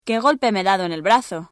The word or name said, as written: brazo